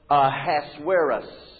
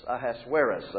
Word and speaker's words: Ahasuerus: And the other guy's name, A-S-U-E-R-U-S. I've heard it pronounced about 3,000 ways, you know. So we're for Grace Church, we're going to call it Ahasuerus. Ahasuerus, that's how we're going to say it.